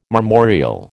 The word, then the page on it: marmoreal: American English